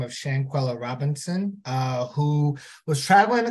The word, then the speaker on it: Shanquella Robinson: Shanquella Robinson.